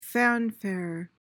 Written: fanfare

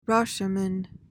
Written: Rashomon

